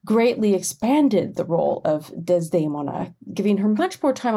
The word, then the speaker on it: Desdemona: Desdemona, and I'll use the Italian pronunciation for the opera character,